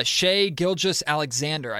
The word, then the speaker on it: Shai Gilgeous-Alexander: There's a player available that I think could be an enticing pick for them at that spot, and that's Shai Gilgeous-Alexander.